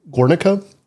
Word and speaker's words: Guernica: Okay, tell me, is it, I'm going to pronounce this wrong because I'm terrible, Guernica? – Mm-hmm. No, you got it.